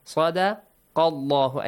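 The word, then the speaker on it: صدق الله: Sadaqallahu. Again, the same as alallahi. The letter lam in the word Allah will be pronounced heavily.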